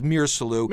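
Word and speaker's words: Misirlou: I've also heard it pronounced other ways, but I've always, the one that always rises to the top is Mirsalu.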